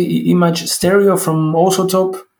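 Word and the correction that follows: iZotope: Stereo Imager from Isotope.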